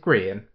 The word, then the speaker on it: Grian: But we one of the YouTubers that I really liked, his name is Grian, which is spelled exactly the same as my name, but with a G instead of a B. – Oh, neat but he pronounces it "green," And um,